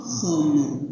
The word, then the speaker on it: khamar: Ini tulisan bahasa Indonesia. Bacanya adalah khamar. Khamar itu bahasa Arab.